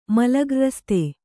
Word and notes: ಮಲಗ್ರ್ರಸ್ತ: mala grasta

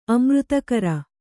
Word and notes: ಅಮೃತಾಕಾರ: amřtākara

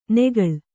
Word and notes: ನೆಗಳ್: kannada

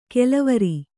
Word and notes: ಕೆಲವರಿ: kelavari

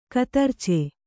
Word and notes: ಕತರ್ಚೆ: katarce; Kannada

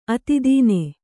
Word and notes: ಅತಿದೀನ: kannada

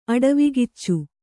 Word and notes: ಅಡವಿಕಿಚ್ಚು: aḍavi kiccu

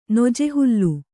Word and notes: ನೊಜೆಹುಲ್ಲು: Kannada

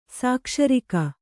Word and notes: ಸಾಕ್ಷರಿಕ: Kannada

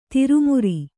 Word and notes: ದುರ್ಮಾರಿ: Kannada

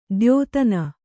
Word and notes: ದ್ಯೋತನ: dyōtana; Kannada

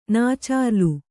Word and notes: ನಾಚಾರ್ಲು: nacārlu

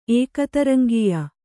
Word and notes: ಏಕತರಂಗೀಯ: ēkataraŋgīya

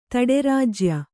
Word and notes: ತಡೆರಾಜ್ಯ: taḍerājya

